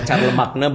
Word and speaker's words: Charlemagne: Oh, Charlemagne bacanya apa? – Charlemagne.